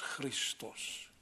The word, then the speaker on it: Christos: Maar het woord Christos werd in die tijd ook al uitgesproken als Christus.